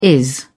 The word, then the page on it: is: British English